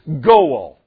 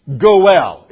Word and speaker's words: goel: The word is goel. Say it with me. Goel is really how it's pronounced.